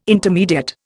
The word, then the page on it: intermediate: Indian